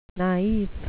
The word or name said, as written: naif